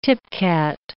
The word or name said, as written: tipcat